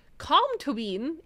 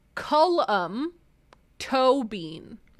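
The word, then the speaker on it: Colm Tóibín: Comtobin is pronounced Cullum-tobin.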